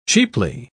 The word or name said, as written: cheaply